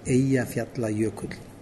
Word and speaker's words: Eyjafjallajökull: I'm going to do it in the way I think it should be done, and it's a shorter name, and it might make it a little bit easier for people to pronounce it. Eyjafjall volcano. And the longer name that people have commonly used is Eyjafjallajökull.